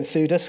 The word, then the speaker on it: Theudas: And I suppose another area of providence is these two people, Theudas. I'm going to call him Theudas because it rhymes with Thudas and Judas.